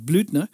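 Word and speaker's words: Blüthner: Goed, een, een nieuwe Bluthner, zoals George het zegt. Dus ik denk, ik denk dat je het op zijn Duits moet uitspreken. Dat is Blutner.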